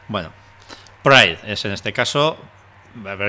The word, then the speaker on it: Pride: Os dejamos, en todo caso, esa recomendación de la peli Proud para... – Pride. – Proud. ¿Cómo se pronuncia eso? – Pride, perdón, sí, eso. Joder. Proud es otra cosa, sí.